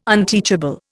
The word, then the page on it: unteachable: Indian